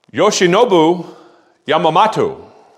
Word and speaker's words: Yoshinobu Yamamoto: And as a follow-up, they got Yoshinobu Yamamoto.